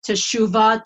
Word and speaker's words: teshuvah: Okay, it's the word shuv, or also it's pronounced teshuvah, to return, but shuv means to return.